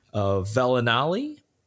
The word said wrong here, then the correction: Vellani: Vellani? – Vellani, yes.